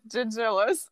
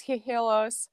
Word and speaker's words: Gigillas: It's Jagillas. – Jagillas. Now I feel like an idiot now. – No, don't. – But for anybody who's listening to this episode, that's the proper way to say the band's name.